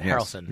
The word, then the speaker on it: Harrelson: On the same line, do you see that Woody Harrelson was added to the new Han Solo movie? – I did not. Oh, Woody Harrelson. – Harrelson.